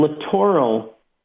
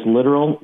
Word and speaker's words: littoral: But from littoral, and people often mispronounce it as literal.